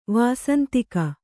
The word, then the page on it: ವಾಸಂತಿಕ: Kannada